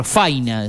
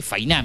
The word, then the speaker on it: fainá: Faina, decían yo, ¿cómo Faina? Digo, sí, es Fainá.